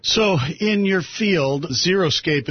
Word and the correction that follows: Xeriscape: Am I saying that right? – You're actually not. No. – What is it called? – It's Xeriscape. – Xeriscape. – And it's not even spelled with a Z. It's X-E-R-I.